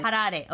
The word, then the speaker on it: Harare: Harare. – Harare.